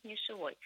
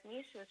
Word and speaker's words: нишевой: Пока в словарях, да, зафиксирована единственная орфоэпическая норма, и правильно произносить «нишевой». Хотя очевидно, что норма это, видимо, проверки времени не выдержит, поскольку в основном все говорят «нишевый» с ударением на корне, да.